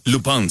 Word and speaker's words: Lupin: Se escribe Lupin. Así como un lupito chiquito Lupín. Oh, bueno, así se llama. Lupin se pronuncia.